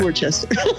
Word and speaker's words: Worcester: Did I say that right? Worcester? Worcestershire. Worcester. – Sure. Why not? – It's Worcester.